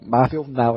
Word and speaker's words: Marvel Now: É Marvel Now. A gente sempre tem que explicar isso aqui, porque a pronúncia fica um pouco, pode dar margem a interpretações errôneas, né? – É, você que é gaúcho, por favor, não se anime, Marvel Now.